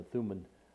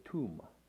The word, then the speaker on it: Thummim: We pronounce it Urim and the Thummim, but it's actually pronounced, hold on just a second. They pronounce the or and the tum. That's how it's actually pronounced in the Hebrew.